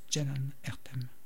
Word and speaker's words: Ceylan Ertem: On poursuit avec Ceylan Ertem, qui s'écrit en passant C-E-Y-L-I-N. Ça s'écrit Ceylan Ertem et ça se prononce Ceylan Ertem.